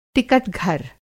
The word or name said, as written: टिकट-घर